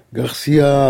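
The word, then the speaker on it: García: Garcia, il ne faut pas prononcer le nom à la française. Garcia Lorca.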